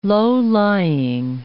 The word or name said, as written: low-lying